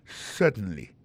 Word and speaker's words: certainly: certainly alors en parlant de accent regardez bien pour un mot certainly accent tombé sur premier syllabe là yes certainly t'aimerais prendre pour encore yes certainly comprenez accent tombé sous premier syllabe là.